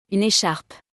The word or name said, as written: Une echarpe